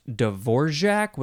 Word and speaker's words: Dvorak: And I always heard it pronounced Dvorak with like a GJ sound in the middle. But I think that might be the more non-English way to pronounce it. It is spelled capital D-V-O-R-A-K.